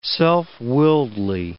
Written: self-willedly

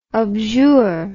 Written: objure

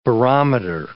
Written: barometer

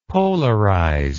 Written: polarized

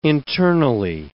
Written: internally

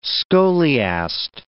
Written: scholiast

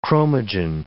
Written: chromogen

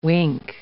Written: wink

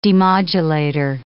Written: demodulator

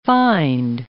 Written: find

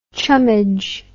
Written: chummage

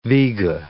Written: viga

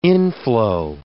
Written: inflow

